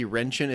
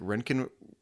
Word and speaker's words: Röntgen: Wrenchen is actually spelled R-O-E-N-T-G-E-N, which is röntgen, like röntgen rays after the guy who discovered x-rays, right? – Oh, okay.